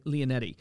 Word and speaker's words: Leonetti: So like Matt, how do you pronounce his Leonetti? – Last name? Leonetti.